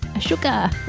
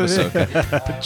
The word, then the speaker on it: Ahsoka: Ahsoka. I barely knew it. – More humour in that line than in the first four episodes of Ahsoka.